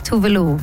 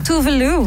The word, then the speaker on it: Tove Lo: The exact is Tove Lo. – Tove Lo.